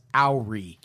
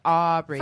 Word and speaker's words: awry: It's pronounced Awry.